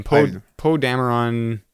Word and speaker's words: Poe Dameron: Poe Dameron.